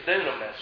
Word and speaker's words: dunamis: In the Greek, it's dunamis, if I'm pronouncing it right. D-U-N-A-M-I-S. Dunamis. And that's where we get the word dynamite.